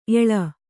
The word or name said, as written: ಎಳ